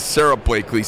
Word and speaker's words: Sarah Blakeley: Our next guest is Sarah Blakeley.